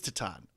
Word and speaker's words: Titane: It is Titane, is how it's pronounced.